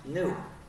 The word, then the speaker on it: GNU: The reason is, according to the dictionary, it's pronounced new.